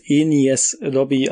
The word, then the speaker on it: Enies Lobby: Naja, das ist hier die, das Bindeglied zwischen der Water 7 und der ähm, Enis, Enies Lobby Arc. Ich habe jetzt erst rausgefunden, dass es Enies Lobby ausgesprochen wird, weil ich jetzt das Japanische vor mir habe.